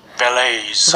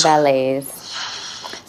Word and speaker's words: Vallaeys: How, do, how do you say his name? Vallaeys. Vallaeys. So, yeah, there's a, probably a really active PPC community there.